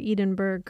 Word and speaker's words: Edinburgh: I don't know how to pronounce that, Edinburgh or Edinburgh.